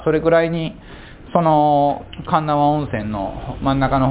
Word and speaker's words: 鉄輪: 神縄温泉、ね、鉄の輪っか、鉄輪と書いて神縄と読みます。